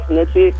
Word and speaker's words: Nietzsche: That man was influenced by the philosophy of, I don't know if I pronounced Nietzsche's name correctly. How did fried rice Nietzsche? – Nietzsche.